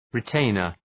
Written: retainer

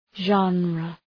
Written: genre

